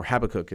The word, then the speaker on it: Habakkuk: I think we just had in the lectionary at Mass this reading from the prophet Habakkuk, or Habakkuk as it's sometimes pronounced, chapter 2, where Habakkuk is kind of like, he's kind of like angry at God.